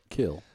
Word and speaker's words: Kill: Three words. Arthur. – Everybody. – One syllable. Kill. – For anybody in the back. – Wait a minute. Arthur's Road. – Two syllables. – Well, yeah, I say one syllable for kill.